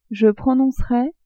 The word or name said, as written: je prononcerais